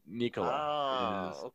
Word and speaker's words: Nikola: But it's really, what's the correct way to say it? – Well, you should say it like you would say Nikola Tesla, right? So that was the guy's name. So Nikola.